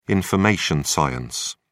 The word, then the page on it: information science: British English